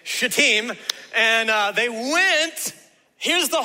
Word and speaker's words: Shittim: This word is pronounced Shittim, okay? So y'all just make sure you put the emphasis on the second syllable, okay?